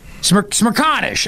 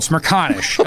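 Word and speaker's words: Smerconish: how do you say that last name? Smirkanish, I'm being told. Smirkanish.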